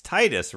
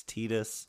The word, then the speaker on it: Tidus: Well, that's why the producer's there for when the voice actor goes, but it's Titus, right? And they're like, no, it's Tidus.